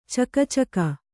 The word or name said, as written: ಚಕಚಕ